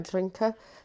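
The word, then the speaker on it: Adinkra: I can't say the word, I'm really bad with pronunciation, but Andinka, is it? Right. Andrinka.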